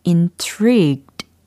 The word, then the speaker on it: intrigued: i n t r i g u e d 아, 발음이 어떻게 될까요? intrigued, intrigued, intrigued. 이렇게 발음이 됩니다.